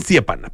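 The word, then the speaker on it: Stjepan: Esa J que está ahí entre medio se pronuncia como una I. – Como una Stepan. Stepan, perfecto.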